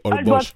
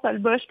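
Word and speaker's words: Holbox: C'est écrit Olbosch quand même. On dit Olbosch. Oui, c'est ça. Ça se prononce Olbosch parce que le X en espagnol là, se prononce comme ça.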